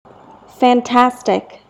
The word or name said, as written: fantastic